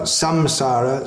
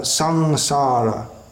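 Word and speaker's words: saṃsāra: Generally, it's pronounced as a something like the N in the word sing in English. So it's not samsara, sangsara.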